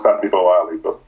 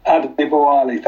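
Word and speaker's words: Adebowale: Lord Victor Adibawola. You're very welcome. I hope I've got that right. Probably not. – It's a good attempt. It's good old Yorkshire name. It's pronounced Adibawali. – Adibawali.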